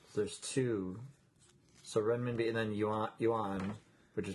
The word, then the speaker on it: Renminbi: Yes, I didn't say it correctly, but that's exactly what it is. It's Renminbi. Right? Is that what it says? – Let's see. Renminbi, yeah.